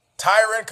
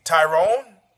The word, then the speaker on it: Tyron: Tyron could be Tyrone. Tyrone. Terrible fight. Hey, listen, word for the wise. The best thing that came out And actually, the way that one of the ways that Tyrone Woodley endeared her,